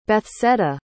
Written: Bethseda

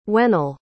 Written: when'll

